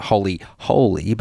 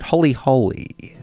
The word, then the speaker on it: Holy Holy: It's always tricky to pronounce that one. It's not Holy Holy, but Holy Holy.